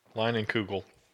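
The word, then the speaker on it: Leinenkugel: How do you pronounce that? – Lightning Kugel.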